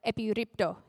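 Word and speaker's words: epiripto (ἐπιρρίπτω): However, the word that Peter chooses here is the Greek word epiripto. Did I say that right, Mum? Yeah. Fun fact, my mum can speak Greek, so I checked.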